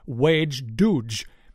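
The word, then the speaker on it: wej Duj: Thanks a lot for the invitation, and here's a quick information on today's episode title of Lower Decks, which is pronounced Wedge Duge, meaning three ships.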